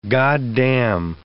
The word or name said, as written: goddam